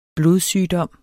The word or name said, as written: blodsygdom